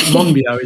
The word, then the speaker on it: Monbiot: George Monbiot, is that correct? – That's it. Yes.